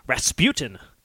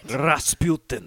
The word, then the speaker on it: Rasputin: I don't trust the people in this song who force Rasputin to rhyme with Queen.